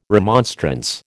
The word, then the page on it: remonstrance: American English